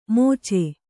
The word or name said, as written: ಮೋಚೆ